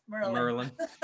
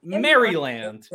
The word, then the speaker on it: Maryland: See, I learned how to say it in a different way. See, Merlin. – M-U-R-A-L. – Shouts to Baltimore for coming up with new words. – Merlin. – Maryland.